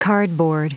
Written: cardboard

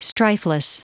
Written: strifeless

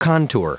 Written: contour

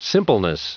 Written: simpleness

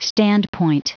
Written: standpoint

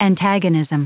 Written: antagonism

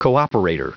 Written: cooperator

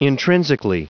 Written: intrinsically

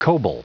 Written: coble

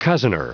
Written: cozener

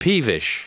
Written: peevish

